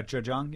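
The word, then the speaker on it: Zhejiang (浙江): In the last part of your book, you travel to Zhejiang province. Did I say it right? – Yeah, Zhejiang, yeah.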